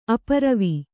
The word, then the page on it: ಅಪೂರುವ: apūruva; Kannada